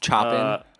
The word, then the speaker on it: Chopin: He plays some Chopin. Is that how you pronounce it? – Yeah. Chopin.